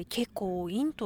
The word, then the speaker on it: 慶光院: あの華やかの花に光に院と書いて慶光院と読むんですけれども、まあ、大通りから一本入ったところを歩いているので結構静かですね。